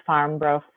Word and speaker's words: Farmbrough: My candidate page is Jen Farmbrough for WRPS School Board, but my last name is kind of tricky to spell, so I have to spell it for people. It's Farm, F-A-R-M, and then B-R-O-U-G-H.